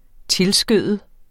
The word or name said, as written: tilskøde